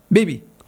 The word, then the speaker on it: Beebe: It's B-E-E-B-E. – Beebe? Beebe. – Is it Beebe or Beebe? – Beebe. – Beebe. – You got to say it like Beebe.